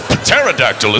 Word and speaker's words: pterodactyl: P-T-E-R. But that's pterodactyl, isn't it? It would be, but you don't pronounce the P. It's silent.